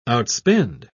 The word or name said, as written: outspend